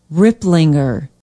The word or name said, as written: riplinger